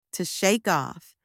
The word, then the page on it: to shake off: anglais